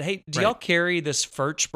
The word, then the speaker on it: Furch: And he's like, well, he goes, that's how I say it too, but the other guy pronounces it, I think he said Furk, but he kind of does something, you know, phlegmy with the C-H, at the end.